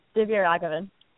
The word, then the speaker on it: Divya Raghavan: It was pretty good, Divya Raghavan.